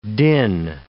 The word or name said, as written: din